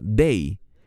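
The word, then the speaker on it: dei (δεῖ): Esta palabra griega que se pronuncia dei.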